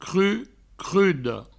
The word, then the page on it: crud: cruda; crudo